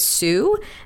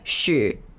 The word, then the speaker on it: Hsu: And then I asked this other person who also has their last name HSU, but he pronounces it Sue, and I was like, okay, how do you say it in Chinese? And he's like, Shu.